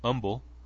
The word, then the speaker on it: Humble: And eventually, cell phone records led the police about 13 miles away into what is spelled as Humble, but is actually pronounced Humble, where investigators said they went door to door looking for the stepmother's vehicle.